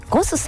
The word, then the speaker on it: GOSS: ゴスさん、 GOSS と書いてね、ゴスさん、そしてお寿司屋さんが 2 店舗、お魚のブリ 2門と書きましてシモンというお店が銀座に 1店舗、日本橋に 1店舗ございます。